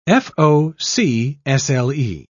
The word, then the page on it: fo'c'sle: British English